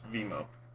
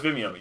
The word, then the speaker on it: Vimeo: It's Vimo. – Are you sure it's not Vimeo? – Oh no, it's Vimeo. Yeah, okay. V-I-M-E-O.